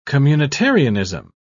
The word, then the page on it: communitarianism: British English